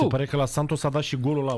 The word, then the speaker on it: Santos: Aici am, se pronunță Santos. O viață întreagă am crezut că se pronunță... Brazilienii nu fac și eu ca portughezii. Adică este limba portughezul-braziliană, da? No. Cum e limba engleză-americană, da? Uh-huh. Atenție, Santos.